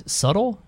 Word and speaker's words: subtle: Subtle? Yeah, I felt like I said it without the B, even though you don't pronounce the B.